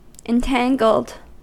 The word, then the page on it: entangled: American English